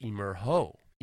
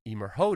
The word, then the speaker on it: Emergo: It's, pronounced, it's spelled Emergo Designs, but I know that it's pronounced Emerho. Emerho Designs.